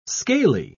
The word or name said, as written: scaly